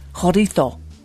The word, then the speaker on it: chorizo: Great show, but I was just hoping to correct a pronunciation of the word for the Spanish meat. It may sound chorizo, but it's pronounced chorizo. It really sounds weird when you say it that way. Hope this does not offend, but I like your show, just don't like that pronunciation. Thanks.